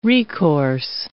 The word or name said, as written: recourse